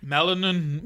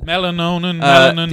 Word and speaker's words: melanin: And also it's to do with like the amount of melanonin. Is it, how do you pronounce that word? – Melanin. Yeah, I think it's melanonin. – Melanin.